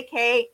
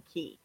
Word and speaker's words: Cay: I always say K, and I need to start saying key. – Castaway key. Even though it's spelled C-A-Y, it's pronounced as the keys.